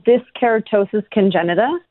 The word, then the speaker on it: dyskeratosis congenita: How do you say this particular kind of disease? – It's called dyskeratosis congenita.